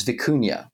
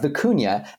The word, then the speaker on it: Vicuña: They have a core LLM, which is Vicuña, which John thankfully told us how to pronounce properly. They've got Vicuña as this kind of main LLM sitting in the middle of their system.